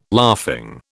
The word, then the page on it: laughing: British English